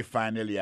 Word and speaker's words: finally: Alors, t'aimes plus finally à vous, non? Finally, c'est F-I-N-A-L-L-Y, finally. T'as des prononciations, oui? Finally. Accent tombé sur premier syllabe là. Finally, F-I-N-A-L-L-Y, ça veut dire en bon créole, enfin, séraphin, pied fin, manger à réussir, arriver sous table là.